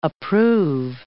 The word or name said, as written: approve